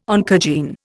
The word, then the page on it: oncogene: Indian